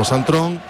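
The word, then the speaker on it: Sint-Truiden: porque el portero Suzuki jugó Santón, es como lo pronuncian los la otra parte de Bélgica.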